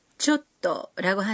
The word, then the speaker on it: ちょっと: 발음은 초 뒤에 숨을 한 박자 멈추면 됩니다. 쫓또라고 하는 거군요.